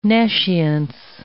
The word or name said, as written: nescience